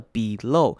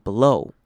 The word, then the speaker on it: below: 好，注意到 below 这边有两种念法，你可以念成影片当中的 below，就是把 e 念成长母音 e 的声音，或者是呢你也可以把 e 变成轻音念成 below 念成稍微的，有没有听到 below 的声音 below。